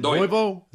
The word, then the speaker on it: doido: Give me that one again. Doido. Is that how you say it? – Doido. Doido. D-O-I-D-O.